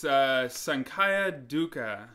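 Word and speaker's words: Saṅkhāra dukkha: Okay, so the first word, I'll put it out there for you. It's Sankara Dukkha. – Sankara Dukkha? – Sankara Dukkha, the suffering that your fear causes.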